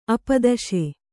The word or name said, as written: ಅಪದಶೆ